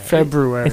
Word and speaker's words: February: It is pronounced with the R. – Yeah, February. – It's February. There's a little R in there.